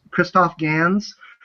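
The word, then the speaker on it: Christophe Gans: This director, I believe the pronunciation is Christoph Gans.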